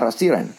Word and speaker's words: rasieren: Rasieren? And they're like, yeah, rasieren.